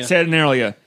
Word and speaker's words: Saturnalia: I always mispronounce it. Sanitarium, the Saturn. – Saturnalia.